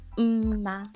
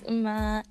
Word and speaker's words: Mma: My name is Mma. I know a lot of you kind of pronounce it, but it's okay. My name is Mma, and I am a Nigerian teenager her.